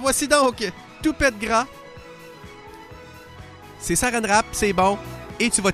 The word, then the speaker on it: Saranerape: Mais je vais le prononcer, je vais te l'épeler plutôt parce que c'est s'écrit pas comme ça se prononce. C'est écrit en phonétique là. S-A-R-A-N-E-R-A-P-E. Saran Rap.